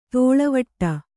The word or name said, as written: ದೂಳುವಡೆ